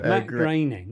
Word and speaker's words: Groening: So Matt Groening, if I'm saying that properly, is... – Groening. You're not, but it's okay. Oh, Groening. – You're Groening. – Groening. – Groening. Matt Groening.